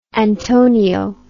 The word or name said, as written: antonio